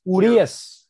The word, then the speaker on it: Urias: What's how you pronounce his last name? Urias? – No. It's not about his last name. It's just Urias.